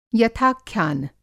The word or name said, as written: यथाख्यान